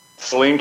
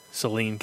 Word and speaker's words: Saline: Saline County. Like Celine Dion. – Right, it's pronounced like Celine Dion, but spelled like the stuff that you squirt up your nose in the winter when you get too many bloody noses, or maybe that's just me.